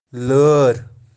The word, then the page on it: laaer: Kashmiri